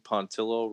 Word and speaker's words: Pontillo: I'll tell you what, if I got in trouble now, my last name, Pontillo, rhymes with armadillo, would be fucking Pontillo in a heartbeat.